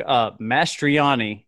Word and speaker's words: Mastroianni: And also, Doug Mastroianni, and I'm hoping I pronounced that right.